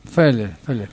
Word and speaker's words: Félez: Félez, con el acento en la primera E.